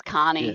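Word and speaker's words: Koni: It's K-O-N-I. Is it Connie or Connie? – It's Connie.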